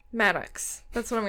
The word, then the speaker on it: Maddux: Maddux? Is that how you'd say it? Maddux? M-A-D-D-U-X? – Sure, yeah.